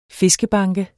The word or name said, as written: fiskebanke